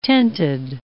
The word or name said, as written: tented